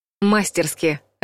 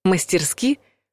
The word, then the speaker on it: мастерски: Мастерски, скажете вы, и мгновенно промахнетесь, если ударение поставите на первый слог. Тот случай, когда ассоциации и мнимые проверочные слова лишь подводят. Если захотите кого-то похвалить, помните про ударение. Мастерски.